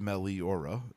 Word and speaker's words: Meliora: Meliora, pronounced Meliora, very important, is a Latin adjective meaning...